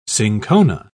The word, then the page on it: cinchona: British English